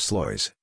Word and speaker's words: Sluis: The Battle of Sluis, Dutch pronunciation, Sluis, also called the Battle of Lecluse, was a naval battle fought on June 24, 1340 between England and France.